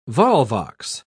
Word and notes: volvox: British English

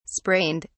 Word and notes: sprained: British English

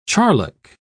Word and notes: charlock: British English

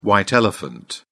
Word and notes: white elephant: British English

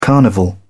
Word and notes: carnival: British English

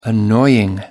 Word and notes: annoying: American English

